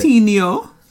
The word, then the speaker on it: Centineo: But Noah Centineo. – Yeah, that's how I would have said it. – Centineo.